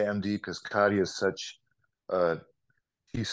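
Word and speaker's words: Kadhja: So you pronounce it Kadia, actually.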